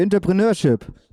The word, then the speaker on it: Entrepreneurship: Hier kommt Zentrum für Jetzt kommt gleich das Zentrum für, Aldo, du weißt es besser, es steht auf dem Zentrum für Entrepreneurship.